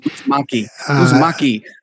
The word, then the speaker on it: Uzumaki: Shadow, and I'm going to say this wrong, Uzumaki? – Uzumaki.